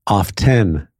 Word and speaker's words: often: How do you say OFTEN? – Often. – Off 10. – No. – It's off 10. – No one says that.